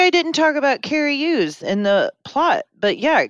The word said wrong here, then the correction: Elwes: How do we say his last name? – Ells. Carrie Ells.